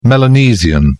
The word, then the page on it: Melanesian: British English